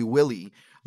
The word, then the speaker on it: Willie: So my friends, you know, teachers and my friends that couldn't pronounce Guillermo would call me Willie. Like Willie was my name in school, like for years. W-I-L-L-I-E.